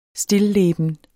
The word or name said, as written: stilleben